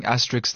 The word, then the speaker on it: asterisk: This is how you pronounce asterisks. – Asterisk. – Asterisk. I have been saying asterisks my entire life.